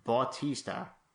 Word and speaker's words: Bautista: One is Jodie Foster, who's a 60 year old nurse, the other is Bautista.